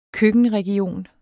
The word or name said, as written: køkkenregion